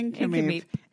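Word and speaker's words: Nk'Mip: Incomeep. N-K. – N-K apostrophe M-I-P pronounced Incomeep.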